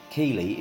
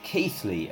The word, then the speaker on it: Keighley: For future reference, the home of television, Keighley, is pronounced Keithley around these parts.